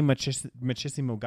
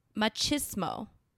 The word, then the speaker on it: machismo: Machismo.